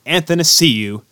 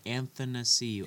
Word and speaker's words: Athanasiou: Andreas Anastasia Athanasiou decides. – I still can't pronounce it. Athanasiou.